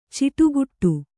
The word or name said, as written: ಚಿಟುಕಿಡು